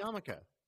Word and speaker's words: Yarmulke: It's not silent. If you were speaking Yiddish, you would say Yarmulke. Yarmulke. Anyway, you say Yarmulke because, you don't pronounce R's if you're from Brooklyn. – So